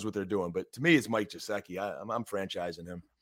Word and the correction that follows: Gesicki: You always throw that soft G at me, and it really throws me off. – Gesicki, my bad.